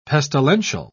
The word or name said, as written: pestilential